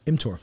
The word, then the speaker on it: mTOR: but the mTOR. Am I saying that right? – mTOR. Yeah.